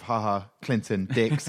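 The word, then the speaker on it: Ha Ha Clinton-Dix: Yeah, because we saw other trades where they didn't have that time to acclimatise, like the likes of Haha Clinton Dix.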